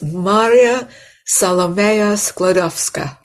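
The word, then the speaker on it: Maria Salomeja Skłodowska: So her name was Maria Salomeja Sklodowska, and she was called Mania at home.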